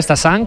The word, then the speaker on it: Stassin: A pronunciarlo bien, Stassin, como nos han apuntado, y Félix Correia, como dice José, con muy buena pronunciación portuguesa.